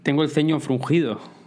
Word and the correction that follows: fruncido: O fruncido, ¿cómo se dice? ¿Frungido o fruncido? Luego lo busco. Fruncido. Bueno, fruncido, tengo el ceño fruncido.